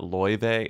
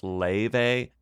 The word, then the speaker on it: Laufey: It's not Laufey. It's Laufey. – Which is okay. It's Icelandic.